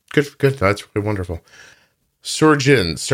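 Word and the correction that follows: Sjögren's: how do you say that? It's S-O-G. Why would you put a J after an O? – Oh, Sjogren's. – Sjogren's. Okay.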